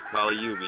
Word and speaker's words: Kali Yuga: Yeah, Kali Yuga. You said it right.